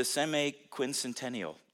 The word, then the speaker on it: semi-quincentennial: 250 years, it's the semi-quincentennial.